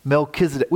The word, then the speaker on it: Melchizedek: Good job, Keegan, by the way, pronouncing Melchizedek. It's a little tricky.